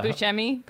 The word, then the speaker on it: Buscemi: Buscemi.